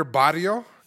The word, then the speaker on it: barrio: You know, I drove down your barrio, okay? I went over there to your, stomping grounds. What did I say? – Barrio? – Barrio? Barrio. – You got to roll your R, buddy? – Fucking Chewbacca.